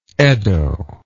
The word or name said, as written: eddo